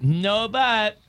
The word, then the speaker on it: Nobu: There's listen, very few women are like no, but I'm telling you, no, but what? – No, but I'm telling you, Spot. Who you asking? – Pretty sure it's pronounced Nobu.